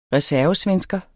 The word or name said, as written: reservesvensker